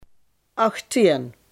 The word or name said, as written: achttien